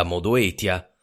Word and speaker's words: Modoetia: Giovanni da Modoetia. Sì, Giovanni, ti sembrerà strana, ma questa è la pronuncia latina ricostruita. Credo che tu probabilmente dici Modezia.